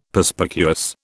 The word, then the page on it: Perspicuous: British English